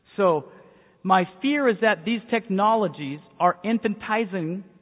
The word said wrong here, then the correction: infantilizing: is that how you say it? Infantilizing, thank you very much.